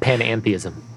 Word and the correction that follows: panentheism: Right. I said that wrong. Panentheism.